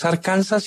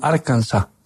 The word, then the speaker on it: Arkansas: Como cuando dicen no es Arkansas, sino Arkansas.